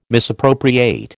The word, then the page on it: misappropriate: American English